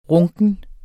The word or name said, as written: runken